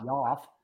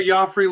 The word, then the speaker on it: Joffrey: It's Joffrey Lupul. – Yeah. How do you say? Not Joff.